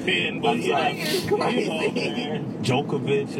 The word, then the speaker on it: Jovovich: I think it's Jovovich. – Jovovich? Yeah, okay. I didn't know you pronounced the J. – You know what? I think if you pronounced it like the way she does, wherever that name is from, I think you don't, but I've heard Jovovich.